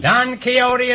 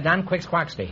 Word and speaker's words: Quixote: Don Quixote is right. Until I was 40 years old, I pronounced that Don Quixquaxty.